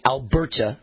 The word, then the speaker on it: Alberta: Okay, data from the Canadian province of Alberta, or I should say Alberta, Canada, stress the right syllable.